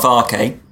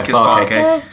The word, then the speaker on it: Farke: I didn't really know much about Daniel Fark. Fark. But I think it's actually pronounced Farke. – Yeah, Farke.